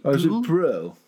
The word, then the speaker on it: Brühl: And then we meet, so he is, like I say, he's totally sober now, and he meets, is it Daniel Brühl? Is that how you say his name? – Is it Brühl? – Yeah.